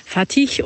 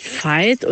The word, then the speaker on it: Fatih: Fatih und natürlich nicht Fight oder wie auch immer ich das vorgelesen habe.